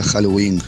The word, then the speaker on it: Halloween: Se pronuncia Halloween.